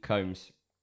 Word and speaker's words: Combs: But nah, seriously, Jeffrey Combs is what I'm saying. – Yeah, sorry, Jeffrey Combs, David Gale's wig has won.